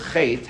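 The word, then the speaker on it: chet (חטא): When it comes to the word chet, ha'alif eino nikar bahavar. When a person says the word chet, the aleph is not pronounced. It's a silent aleph.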